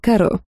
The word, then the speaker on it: Каро: «Я герцогиня Аннушка Каро», — ответила темная фея вместо Карла, делая ударение на последнюю букву в своей внезапно укоротившейся фамилии.